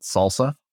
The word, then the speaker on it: SLSA: So SLSA, and you pronounce it salsa.